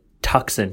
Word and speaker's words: Tucson: Tucson, but it's pronounced Tucson, but it's the S and the C are backwards.